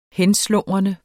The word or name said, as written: henslumrende